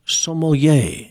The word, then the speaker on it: sommelier: You know, and she is a credentialed, what they call a tea sommelier.